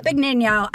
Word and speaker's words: Benigno: I mean, Joe Benigno, I said it wrong, but here we go. – No, that's the Italian. – Right? No, okay, that's great. The Italian. That we like that better. It sounds more sophisticated.